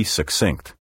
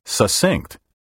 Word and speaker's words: succinct: By the way, I'm sure you've heard the beastly mispronunciation succinct, which in recent years has become widespread among educated speakers. We don't say accident for accident, accept for accept, or secede for succeed. So there's no logical reason for saying succinct. Take care to pronounce the double C like KS, succinct.